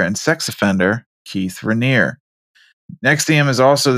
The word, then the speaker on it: NXIVM: That's how you say it, right? Nexium. – Yeah. – Sure as fuck this not spelled that way. Just so everybody knows. Yeah, I don't know why they spell it like that. It really confuses me. There's letters in there that do not make that sound. It's NXIVM. I don't know how that spells Nexium, but I'll take it.